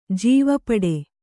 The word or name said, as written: ಜೀವಪಡೆ